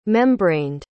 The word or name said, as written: membraned